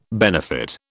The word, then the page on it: benefit: British English